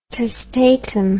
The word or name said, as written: testatum